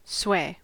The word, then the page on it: sway: American English